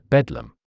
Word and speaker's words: Bedlam: Londoners later abbreviated this to Bethlehem and often pronounced it Bedlam.